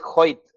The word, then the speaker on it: Hoyt: Oye, mi, apellido es Hoyt, se pronuncia como si fuera J.